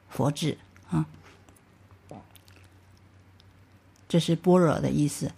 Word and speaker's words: 般若: 所以呢，他为了不产生歧义，所以就没有译成这个，没有意义啊，没有译成按意思来译，而是就保留他的发音般若，有的发成般瑞啊。